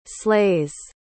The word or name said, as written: slays